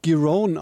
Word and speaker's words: Giron: Wer bitte ist Marcus Giron? Giron? – Ja, er spricht sich Giron aus tatsächlich